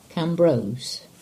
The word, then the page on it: Cambrose: English